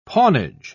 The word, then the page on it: pawnage: British English